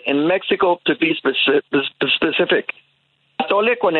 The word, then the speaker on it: atole: Atole.